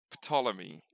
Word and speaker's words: Ptolemy: Which, Ptolemy. It's P-T-O-L-E-M-Y.